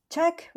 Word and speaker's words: Czech: Czech Republic 捷克， OK，这个 check 我觉得很多人都会发不出来。那你只要想，它就是那个 checking 的那个 check，那个发音是一样的。So 就是 Czech Republic，就是捷克共和国。